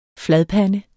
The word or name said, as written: fladpande